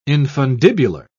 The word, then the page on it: infundibular: British English